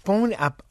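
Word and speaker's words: bon appétit: Now, how about leaving off the final T of bon appetit? – Oh, bon appetit. Oh, I say bon appetit.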